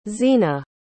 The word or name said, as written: zena